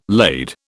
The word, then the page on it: laid: British English